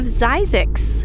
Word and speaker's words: Zzyzx: And now we're going to head northeast from the San Fernando Valley into the desert to the town of Zizix. That's spelled Z-Z-Y-Z-X.